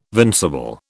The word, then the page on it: vincible: British English